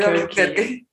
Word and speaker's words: Albuquerque: ¿Cuál es la pronunciación de Arburquerque? – Alburquerque, ¿no? – Sí, pero no sé por qué se me subió el tingo de quesadillas. Ahorita que quise decir Albuquerque.